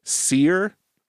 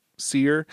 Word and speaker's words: Cyr: but I mispronounced it. It is in fact pronounced Seer, which is C Y R Seer, mm-hmm.